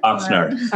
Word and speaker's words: Oxnard: Oxnard.